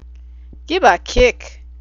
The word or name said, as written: gibakick